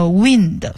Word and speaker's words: wind: 好，最后一个是 winding, 这个 wind, W-I-N-D, 大家比较熟悉的是那个 wind, 风这个词，但是呢它发音发成 wind, 它是一个动词，是上弦的意思，就是比如说原来老式的钟或者手表你要上弦它才能够走就是上发条，那么这个是 wind。